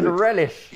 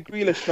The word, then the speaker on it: Grealish: No, Grealish. I didn't say that. Grealish, man. Grealish. – You said Grealish. – Man said Relish. – You said Grealish, bro. – Okay, I'll say Grealish, man.